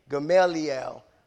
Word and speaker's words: Gamaliel: Gamaliel is the proper pronunciation of his name.